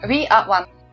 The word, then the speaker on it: We Are One: Надеюсь, э, что, как и я, многие, вообще очень многие люди, благодаря вот этому проекту We э, Are The One, или We Are One, как он там правильно произносится. – We Are One. – One, да, при, э, прикоснутся к этому фестивальному кино, поймут, что это круто, и потом чаще будут как бы обращать внимание на такие события.